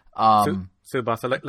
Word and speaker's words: Tsubasa: Tsubasa, like Tsunami. – Right, Tsubasa, yeah. – Without the Nami.